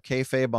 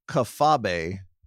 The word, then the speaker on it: kayfabe: Listener James Raddick writes in, I feel it should be brought to your attention that Nate Silver also brought up kayfabe on the 538 Politics Reaction Pod, but pronounced it kafabe. Kafabe.